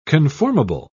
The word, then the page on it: conformable: British English